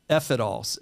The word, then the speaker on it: Ephitol: F It All. Like it could be, I guess it could be like, you know, skewed as fuck it all, but it's spelled almost like a pharmaceutical, right? F It All.